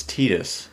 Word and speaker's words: Tidus: How do you think his name is pronounced? – It's Tidus. – See, this game does say Tidus, and this game actually did that whole controversy, because a lot of people been calling him Tidus. – It's always been Tidus. It's just that in America, you know, pronunciation's a little different, so you have to approach these names from a Japanese kind of standpoint in terms of language.